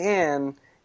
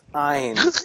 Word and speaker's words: Ayn: It's when someone whose name is clearly Anne asks to have it pronounced Ayn.